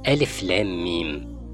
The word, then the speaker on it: الۤمۤ: ألف لام ميم ليه ما كتبناهاش ألف لوحدها ولام لوحدها وميم لوحدها أو ألف لام ف لام ألف ميم ميم ياء ميم فتتنطق زي ما هي مكتوبة ألف لام ميم أصل الحروف البسيطة اللي